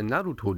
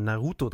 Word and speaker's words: Naruto: Ja, es ist, na, es ist schon Naruto, aber in aus mein, die deutsche Betonung gerne auf die zweite Silbe, deswegen wird dann Naruto draus.